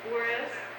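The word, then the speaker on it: Juárez: Is it Juarez? – Yeah.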